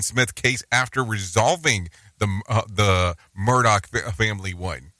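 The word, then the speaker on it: Murdaugh: Yeah, Murdoch, but that's not the way that it looks.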